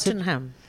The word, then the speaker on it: Tottenham: How do you pronounce Tottenham.